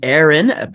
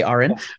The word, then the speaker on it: Aren: My name's Aren. People often say Aren, but sorry, it's it happens all the time. My name's hard to pronounce.